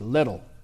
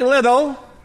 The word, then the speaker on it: Liddell: That's how you pronounce it. It's not Liddell, it's Liddell. Eric Liddell was one of the greatest runners of his generation.